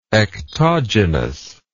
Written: ectogenous

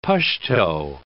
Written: Pashto

